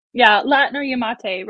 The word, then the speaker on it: Latno-Yamate: Latno-Yamate rhymes with karate.